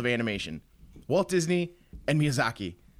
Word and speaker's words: Miyazaki: Yeah, Miyazaki.